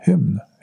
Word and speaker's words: Hymn: Jag gillar den där Hydm. Hydm jag, kan, jag kan inte uttala det. Hymn, Hymn heter det.